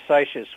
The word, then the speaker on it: Seixas: Gershom Mendes Seixas, or how do you pronounce that? – Seixas. Yes. Gershom Mendes Seixas was really, in a sense, the first great American Jewish religious leader.